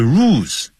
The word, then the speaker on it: rules: Or grammar rules, 或者是文法规则 ,rules, 这个有一个 L 嘛，所以不要念成 rules，叫 rules，要念欧洲的欧，但舌尖是抵上去的。 rules 就像 girls，女孩子，she's a beautiful girl，不要念 girl，不是 girl，舌尖抵上去，所以 rules。